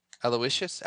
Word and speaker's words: Aloysius: A-L-O-Y-S-I-U-S. Aloysius?